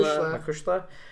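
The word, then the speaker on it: mo chuisle: He gets her a robe to wear to the ring with the Gaelic expression Makushna, is that how you say it? Makushla, embroidered on the back, but will not tell her what it means.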